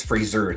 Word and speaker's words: Fraser: The reason why I'm bringing it up is because I have a theory on Brendan Fraser. It's Fraser. It's not Fraser. It's Fraser. Brendan Fraser. The Golden Globe. Yeah, it's Fraser. He's cleared it up. It's not Fraser. It's Fraser. Like a razor.